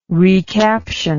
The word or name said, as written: recaption